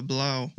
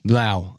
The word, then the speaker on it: blau: It's German for so it's pronounced uh, blau. – Blau. – Okay. But it's blau. German for blue.